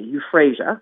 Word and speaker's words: euphrasia: It's spelled E-U-P-H-R-A-S, as in Sam, I-A, euphrasia.